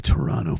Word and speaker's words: Toronto: You know the Toronto people. I'm from Toronto. We all call it Toronto. We don't even say the other T because it's not even worth fucking pronouncing.